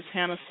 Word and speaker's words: Hannah: which is Hannah Spivey. My first name is spelled H-A-N-N as in Nancy, A-H.